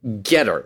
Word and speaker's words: Gettr: The most recent one came out just a few days ago in a soft launch called Getter. I think that's the way you're supposed to pronounce it. It's spelled G-E-T-T-R.